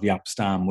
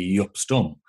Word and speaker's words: Jaap Stam: The right. Dutch pronunciation of Jaap Stam would be Yup Stum. And I do know of a commentator close to me here who started calling him Yup Stum, which was the correct pronunciation. But the, the received pronunciation, like in England and stuff, everybody called him Jaap Stam.